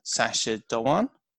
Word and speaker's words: Sacha Dhawan: Who's Sasha Dhawan. – Yep. – I didn't say that right, did I? – You did say that right, yeah.